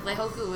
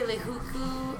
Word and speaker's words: Leihoku: Like I mean, oh my god, Leihoku was lekiki, Lehuka, Lahaka, and, and, and sometimes you, you, when people say it so wrong, you're like, it's literally how it is spelled is how it is pronounced. so when people would say Lehuku or Lehuka or like Lehaku, it's literally L E I H O K U.